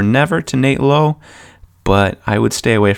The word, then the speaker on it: Lowe: Brandon Lau spells his last name exactly the same, but pronounces it Lau. Nate pronounces it Lowe.